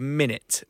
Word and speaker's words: Minit: It's a game called Minute. Right. It actually came out last year. It's spelled M-I-N-I-T, so minutes spelled I-T.